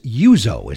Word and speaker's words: Uzzo: All right, but it's spelled U-Z-Z-O. – That's right. It's really, I guess phonetically it would be Uzo, but we always pronounced it Uzo.